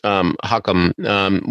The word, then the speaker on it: Chu Chem: C-H-U-C-H-E-M, but yeah. It's pronounced Hukum, which is Yiddish for wise man.